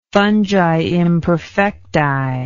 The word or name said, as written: fungi imperfecti